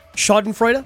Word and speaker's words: Schadenfreude: Schadenfreude? – Yep.